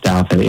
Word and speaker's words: Douthat: I'm sure you saw this being interviewed by was it Ross Douthat? I don't know if I say his name. – Yeah, correctly. Ross Douthat.